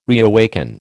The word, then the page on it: reawaken: American English